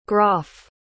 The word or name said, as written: groff